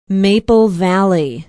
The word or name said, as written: maple valley